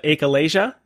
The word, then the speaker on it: achalasia: And then also, you know, the, if I say this correctly, the achalasia, is that right? – Yeah. Right.